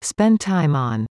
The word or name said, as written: spend time on